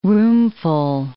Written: roomful

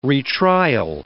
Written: retrial

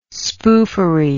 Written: spoofery

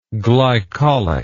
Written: glycolic

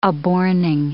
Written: aborning

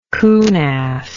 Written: coonass

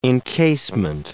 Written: encasement

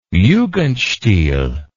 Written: jugendstil

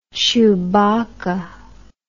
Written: Chewbacca

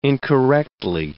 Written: incorrectly

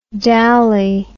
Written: dowly

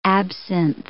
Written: absinth

